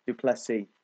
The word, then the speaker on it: du Plessis: du Plessis.